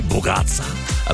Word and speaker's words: bougatsa: I was not sure I was pronouncing the name of that Greek dessert properly, bougatsa. Apparently, I was doing a pretty good job, but she knows because she's been married to a Greek family for 25 years and sometimes still struggles.